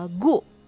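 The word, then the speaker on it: gu (구): Nah, sembilan dalam bahasa Koreanya salah satu penyebutannya adalah uh, gu gitu ya sembilan dalam bahasa Korea terutama dalam uh, angka Sino Korean itu disebutnya gu gitu jadi gong sama gu itu nol sembilan Lalu 10.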